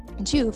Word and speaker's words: Joovv: Juve, that's spelled J-O-O-V-V,